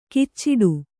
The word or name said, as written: ಕಿಚ್ಚಿಡು